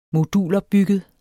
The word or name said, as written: modulopbygget